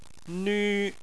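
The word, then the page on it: nv1: tone 1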